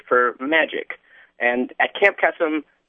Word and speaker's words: Kesem: Am I pronouncing that correctly? – Yep, Camp Kesem, as in K-E-S-E-M.